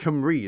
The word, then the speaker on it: Cymru: as in, well, you're probably right actually because it's Cymru, isn't Wait.